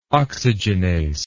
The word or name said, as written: oxygenase